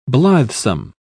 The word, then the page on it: blithesome: British English